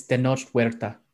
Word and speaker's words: Tenoch Huerta: So anyone as far as how to pronounce it, it's Tenoch Huerta. – I got the second part right, I think. Only I may have been too hard on the H.